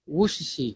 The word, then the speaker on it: Worcestershire: Well, it's Worcestershire. My wife just pulls me up on it. – Say it again. – Worcestershire. – That was actually pretty good.